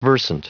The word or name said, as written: versant